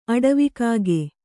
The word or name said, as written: ಅಡವಿಕಾಗೆ